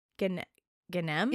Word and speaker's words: Ghanem: So, perhaps the best known story of childhood past life memories is that of Hanan Mansour and Suzanne Ganahem. Ganahem? – How do you spell it? – G-H-A-N-E-M. – Ganem? – Yeah. Ganem? – Ganem? – Like Gangnam, but Ganem? – Yeah. – Ganem style? Suzanne Ganem.